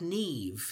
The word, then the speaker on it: Niamh: I mean, like the name, I'm looking at it right now, in Irish is spelled N-I-A-M-H, which I would think would be like Niam or something. And it's pronounced more like Neve.